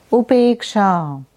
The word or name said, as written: Upeksha